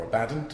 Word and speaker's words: Abaddon: It's being published by Abaddon Books, or Abaddon, depending on how you pronounce these things.